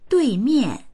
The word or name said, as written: dui4mian4